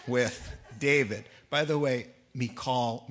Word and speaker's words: Michal: Now Saul's daughter, Michal, is how you say the word in the Hebrew. Everyone calls her Michal. It's Michal. But I'll call her Michal just so we're all comfortable.